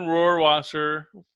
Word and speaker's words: Rohrwasser: So Justin Rohrwasser, that's how you say it, was ranked third best kicker.